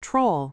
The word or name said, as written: troll